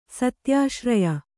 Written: ಸತ್ಯಾಶ್ರಯ

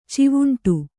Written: ಚಾವುಂಡಿ

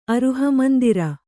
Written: ಅರುಹಮಂದಿರ

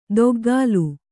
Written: ದೊಗ್ಗಾಲು

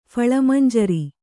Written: ಫಳಮಂಜರಿ

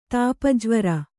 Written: ತಾಪಜ್ವರ

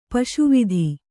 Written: ಪುಸಿವಾತು

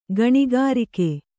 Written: ಗಣಿಕಾರಿಕೆ

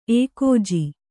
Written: ಏಕೋಜಿ

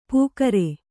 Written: ಪುಕಾರೆ

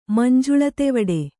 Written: ಮಂಜುಳತೆವಡೆ

